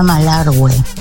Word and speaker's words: Malargüe: Se pronuncia Malargüe. Jota. – Ah, viste, me parecía. Me parecía porque me lo escriben como Malargue y era como que tenía una leve sospecha por ahí que era Malargue.